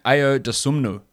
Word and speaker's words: Ayo Dosunmu: Ayo Dosunmu. Is that how correct is that for a pronunciation for the Illinois guard? – That was pretty good.